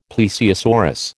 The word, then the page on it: plesiosaurus: American English